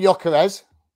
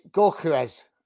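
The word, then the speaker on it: Gyökeres: How do you say that last name? – Oh, yeah, so we call him Jokeres. – Okay. What did I say? Jokeres. – Yeah, but if you play FIFA, they say Gorkeres.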